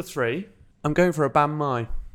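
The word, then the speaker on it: bánh mì: Banh mi is how I think most people I've heard say it. But apparently it's born mi. – Yeah. – That's how you pronounce that.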